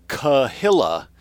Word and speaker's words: Kehillah: If the Kehillah, and that's how they say even though it's spelled K-E-H,